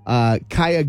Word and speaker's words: Kaia: How do you say her first name? K-A-I-A? – Kaya? – Kaya? Kaya Gerber is her name.